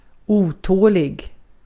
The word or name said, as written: otålig